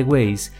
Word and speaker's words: Waze: El nombre de Waze proviene de la pronunciación de la palabra ways, caminos o vías en inglés. Al pronunciarlo, suena similar.